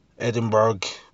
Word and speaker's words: Edinburgh: Edinburgh. One of those. There's another one of those towns, places, words in English that isn't pronounced how it's spelt.